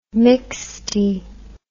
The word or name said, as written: mixte